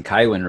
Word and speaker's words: Kywin: Yeah, you explained the whole name thing to me when I was asking you if I was pronouncing Kywin right. And a lot of people were telling me that the audiobook guy was saying Cohen, and I was like, how are you getting Cohen out of that?